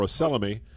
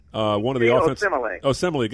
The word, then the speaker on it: Osemele: You mentioned in just passing Kelechi Olesemi, I believe that's how you pronounce his name, or Osemele. Osemele, got an unusual name, an offensive line.